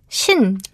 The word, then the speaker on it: 신: Yes, which is Shin.